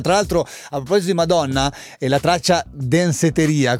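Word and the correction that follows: Danceteria: come la pronuncio io, ma scritto ancora Franciosi, mi fa, guarda che si chiama, com'è? – Danseteria,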